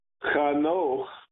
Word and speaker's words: Chanoch: My name, Chanoch, is spelled in English C-H, just like Hanukkah.